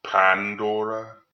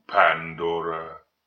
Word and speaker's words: Pandora: You have put aside your own agenda, Pandora? – It was odd how he accentuated the first syllable of her name.